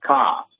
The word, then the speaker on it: Cairns: I did watch the sun come up over the Pacific Ocean this morning in Australia, or Cairns, depending on how you pronounce it. Locally, they pronounce it softly, like Cairns.